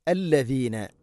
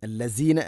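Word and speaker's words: അല്ലദീന (الذين): അല്ലദീന, അല്ലസീന അല്ല, അല്ലദീന ആമനുത്തഖുല്ലാ.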